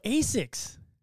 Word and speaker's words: ASICS: I either like Nike or the, I don't even know how to say it, the ASCI. – Oh, ASICS.